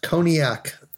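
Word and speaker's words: Koniak: It's a K-O-N-I-A-K, pronounced Cognac. Sounds like Cognac. The drink, but spelled differently.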